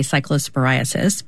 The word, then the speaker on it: cyclosporiasis: If you are worried, there is a CDC, cyclosporiasis, I didn't pronounce that right, outbreak page. Cyclosporiasis. Yes.